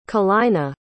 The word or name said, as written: kalina